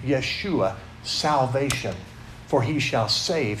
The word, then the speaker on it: Yeshua: If you say it in Hebrew, it's pronounced Yeshua. That's as close as I can come to pronounce it anyway.